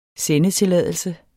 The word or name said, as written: sendetilladelse